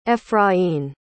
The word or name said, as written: efrain